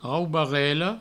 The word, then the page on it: raubarela: raubarello